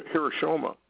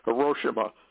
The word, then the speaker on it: Hiroshima: And the Bible says that the America Babylon is rendered what she gave out in Japan when we took out Nagasaki and Hiroshima. And that's not how you pronounce that, Hiroshima, and with two nukes.